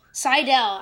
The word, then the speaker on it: Seidel: And to discuss that with me, all the way from Alabama, we have Mr. Phil, is it Seidel? – It's Seidel.